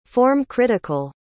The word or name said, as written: form-critical